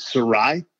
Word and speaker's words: Sarraille: So a lot of people think it's Italian. It's actually Basque, which is a area really? Between Spain and France. Yeah. So the true pronunciation is Sarraille, but as we immigrated to the U.S., it got Americanized, and family is a bit of a pushover.